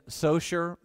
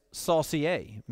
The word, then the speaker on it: Saucier: For instance, I live in Socher, Mississippi. And Alexa says that it's Saucier, Mississippi, which sounds a little French.